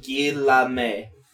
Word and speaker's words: Guillaume: Robert Guillemain, There's no N. – Guillemain. Look, I can't pronounce his name. Guillaume.